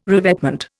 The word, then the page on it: revetment: Indian